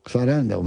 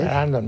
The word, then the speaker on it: Sarandon: Sarandon, ¿ves? – Sarandon, ¿ves cómo tenía razón? – Claro.